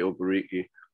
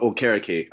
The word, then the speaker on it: Okereke: I think you'll be able to show Bobby Okereke, well, Okereke, I'm sorry, since we're pronouncing last names right today on this episode.